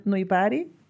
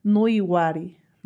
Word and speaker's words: Nuiwari: Ellos, los Wirráricas lo pronuncian Nuibari y los Nayaris lo pronuncian Nuiwari, ¿no? Como se escribe.